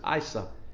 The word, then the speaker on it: Isa: It's pronounced Isa in the Quran.